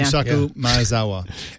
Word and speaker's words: Yusaku Maezawa: Yusaku Maezawa. Am I saying that right, yeah. Amy? – Kind of, yeah. – Yusaku Maezawa.